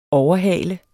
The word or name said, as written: OVERHALET